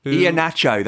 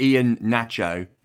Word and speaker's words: Iheanacho: Iheanacho, that's right, James. Iheanacho, not Ian Nacho, not Iheanacho, Iheanacho.